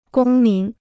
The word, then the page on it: 公民: gōng mín